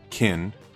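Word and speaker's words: Qin: I believe it's Kin. It's Q-I-N.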